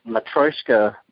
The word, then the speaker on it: Matryoshka: You know, I shared the introduction with folks, but you know, one thing I think is really amazing is that the Matryoshka figure, am I saying that right? – Yes.